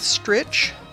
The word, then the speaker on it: Stritch: I am in the home stretch, which kind of rhymes with stritch.